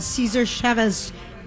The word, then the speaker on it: Cesar Chavez: We are out on the road at El Burrito Mercado on Cesar Chavez. Is that how you say it, Melissa? – Yes, you C- said it perfectly.